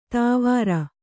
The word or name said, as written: ದೇವರ